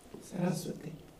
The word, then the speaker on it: Sarasvati: So we are invoking the goddess Sarasvati, and I'm supposed to get the emphasis on that it's a long ah, so I have trouble pronouncing Sanskrit, Sarasvati somehow. – Sarasvati. – Right, but that, yeah, and the ah is supposed to get it.